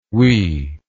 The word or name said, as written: oui